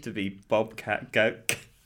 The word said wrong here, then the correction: Goldthwait: I can't say his name. Bobcat Goldthwait, or whatever his name is, from Police Academy 2.